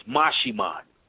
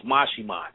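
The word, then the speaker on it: Maximón: And I explained the entity name is, it looks like it's Maximon, but it's Mashimon. It's pronounced Mashimon.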